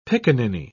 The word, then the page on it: pickaninny: British English